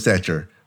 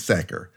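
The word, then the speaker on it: Sachar: I think it's Lewis Sacker. I think it's Sacker.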